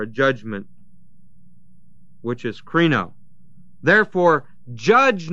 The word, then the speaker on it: krino: And that word judge is krino, K-R-I-N long O, which is to pronounce a sentence or a judgment.